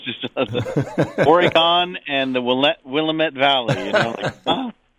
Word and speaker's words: Willamette: It's nice to hear Willamette pronounced the right way.